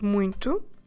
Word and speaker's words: muito: M-U-I-T-O. Muito. You might have noticed that when we pronounce this word, there's a nasal sound, muy, but when we actually spell it, there's nothing there to tell us about that nasal sound.